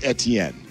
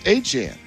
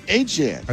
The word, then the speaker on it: Achane: I'm going to try like hell to call new Saints running back this year Travis Achane, because he says it's not pronounced Etienne. He says it's pronounced Achane. Okay, then let's pronounce it Achane.